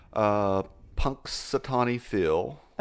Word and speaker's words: Punxsutawney Phil: Punxsutawney Phil.